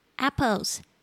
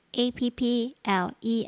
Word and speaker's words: apple: 好，接下来我们讲 fruits apples A P P L E S 苹果。这边大家注意一下，那个 A 的声音是 a，不要念成 A，不是 apple，是 apple，那个舌头稍微向下压。